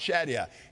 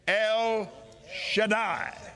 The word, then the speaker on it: El Shaddai: That's the correct pronunciation. Not Shaddai, not Shaddia. It's El Shaddai. Say it. El Shaddai.